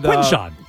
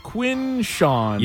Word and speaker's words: Quinshon: I can't say his name, but he had Quinshon. – Quinshon. – Yep. – I try to speed through the Quinn part.